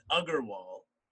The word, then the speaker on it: Agrawal: So the way my growing up, it's always been Agrawal, but the spelling it varies for all the Agrawals.